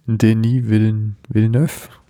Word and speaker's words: Denis Villeneuve: Ein neuer Film von Denis Villeneuve. Mhm. Ist richtig ausgesprochen? Wird das ausgesprochen? – Ja.